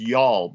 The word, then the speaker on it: Youghal: That, that town's pronounced Yall, by the way.